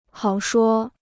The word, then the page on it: 好说: hǎo shuō